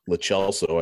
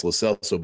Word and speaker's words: Lo Celso: When he first came to Spurs, I remember there was some debate about how to say his name. I had been like just reading it as Lo Celso. I thought that was the right thing. And then it was made clear it's Lo Celso.